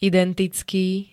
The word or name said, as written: identicky